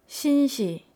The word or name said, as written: sinsi